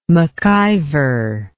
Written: maciver

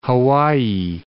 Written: Hawaii

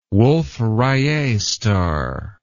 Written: wolf rayet star

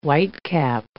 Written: whitecap

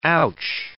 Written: ouch

